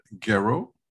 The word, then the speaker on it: Gairaud: We are here with Robin Gairaud.